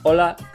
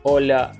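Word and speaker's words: hola: Э, ола, как вы заметили, произносится ола, ола. Элка не твердая, ни ола, ни оля, а ола, л, л, ола, ола.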